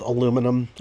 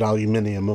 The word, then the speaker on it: aluminium: aluminum. Actually, they pronounce it aluminium, of course, because they're British and all.